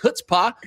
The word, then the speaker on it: chutzpah: He has not reached a point in life to know that the C is silent. I didn't realize it was chutzpah until like 4 years ago.